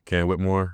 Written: Cam Whitmore

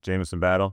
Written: Jamison Battle

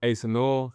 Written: Asa Newell

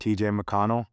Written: TJ McConnell

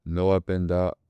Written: noah PENDA

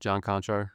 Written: john KONCHAR

